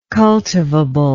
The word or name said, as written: cultivably